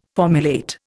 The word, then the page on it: formulate: Indian